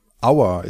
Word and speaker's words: Auer: Our is spelled A-U-E-R. Our is an old German last name, and also many towns, lakes and rivers in Germany carry that name. But of course it sounds like the English genitive of us, our.